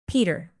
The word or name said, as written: peter